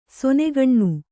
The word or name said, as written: ಸಣ್ಣಗಣ್ಣ